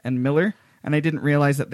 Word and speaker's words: Millar: "It's Miller Time," but it was spelled Millar, and then they even mentioned in the article, yes, it's pronounced like the beer.